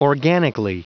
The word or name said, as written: organically